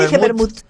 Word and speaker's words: vermú: No me pongas la T como se escribe. – No, dije vermú. Dije vermú.